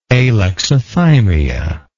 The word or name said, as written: alexithymia